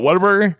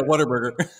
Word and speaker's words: Whataburger: And for those of you who are not in Texas, say it again so that they can hear it properly. – I'm sorry. What? Whataburger? – Yeah, Whataburger. – Yeah. There we go. That's how you say it. – What a burger. There you go.